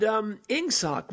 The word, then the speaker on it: Ingsoc: It was the shorthand for English socialism, but instead of being spelled E-N-G-S-S-C, as in the way we pronounce E-N-G in English, they had tightened it up to make it look exactly like it sounds, or sound like it looks, Ingsoc, I-N-G-S-S-C.